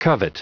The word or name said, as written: covet